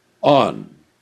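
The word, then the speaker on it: on (ἄν): There is a little Greek word, A-N, pronounced on, alpha nu.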